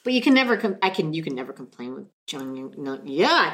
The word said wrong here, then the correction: Jang Young Nam: Jang Young Nam. Jang Young Nam.